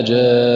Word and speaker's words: جان: وكذلك من الأخطاء الشائعة كلمة ولا جان في سورة الرحمن أيضا في حالة الوقف، فالبعض ينطقها خطأ: ولا جان بقلقلة النون. والصواب هكذا: ولا جان، ولا يرفع اللسان إلا بعد أن ينتهي من نطق النون.